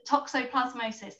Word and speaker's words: toxoplasmosis: Yeah, it's toxoplasmosis.